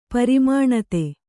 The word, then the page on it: ಪರಿಮಾಣತೆ: parimāṇate